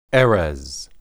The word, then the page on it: erez: hebrew